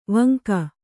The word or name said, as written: ವೆಂಕ